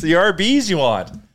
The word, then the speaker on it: Arby's: About how Arby's, spelled A-R-B-Y-S, is pronounced R-B's, because that's how you would spell R-B's, and Arby's for roast beef.